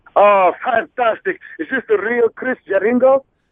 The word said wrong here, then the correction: Jericho: It's actually Jericho, but I know you're from Italy, and sometimes you have a problem pronouncing names, but Jericho. – Jeringo. – Jericho.